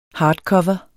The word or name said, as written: hardcover